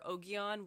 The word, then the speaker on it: Ogion: And then she said it was like the rhyme for Ogion was bogey on.